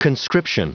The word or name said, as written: conscription